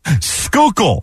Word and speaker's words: Schuylkill: I will say you pulled out the victory there, but can you pronounce the word that I asked you guys to pronounce? Do you remember how to say it? – I cannot pronounce it. – Schuylkill, as in Schuylkill Expressway or Schuylkill River.